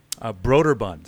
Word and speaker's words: Broderbund: Broderbund.